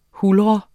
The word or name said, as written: huldre